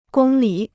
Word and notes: 公里: gōng lǐ

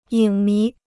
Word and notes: 影迷: yǐng mí

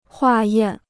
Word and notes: 化验: huà yàn

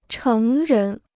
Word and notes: 成人: chéng rén

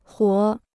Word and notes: 活: huó

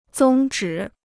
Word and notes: 宗旨: zōng zhǐ

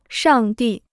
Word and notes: 上帝: shàng dì

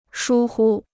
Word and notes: 疏忽: shū hu

